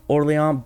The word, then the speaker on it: Orléans: And so in the times of his delirium, his brother Louis of Orléans, and I realize, you know, it's pronounced Orléans, but with his name being Louis, I want to pronounce it Louis of Orly.